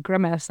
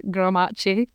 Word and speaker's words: grimace: But Grimace is one of those words that like I pronounced so wrong for like 10 years because I'd only ever seen it in books that I was reading. And then somebody said Grimace and I was like, oh, that's what that is. And I was like, I didn't actually pronounce it like this, but because it ends in A-C-E, I was like, Grimace.